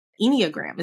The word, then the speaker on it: Enneagram: So Today we're going to talk about the Enneagram. Is that how you pronounce it? – That's how you pronounce it.